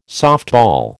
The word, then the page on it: softball: American English